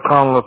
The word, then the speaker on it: Conliffe: I just spell it Conlon. C-O-N. Like Nancy. – Okay. – L-I. Conlon. C-O-N-L-I-F-F-E. – F-F-E.